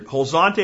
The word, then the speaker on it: Huazontle: Wolzante, if you want to look it up that way, is how you spell it. Huazontle.